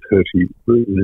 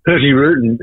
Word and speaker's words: Hurtigruten: Hershey Rutan, incidentally, it's a Norwegian company. It's spelt H-U-R-T-I-G-R-U-T-E-N. – Yes. – But I'm reliably informed one doesn't pronounce the G.